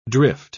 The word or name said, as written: drift